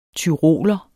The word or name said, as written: TYROLER